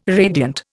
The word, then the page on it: radiant: Indian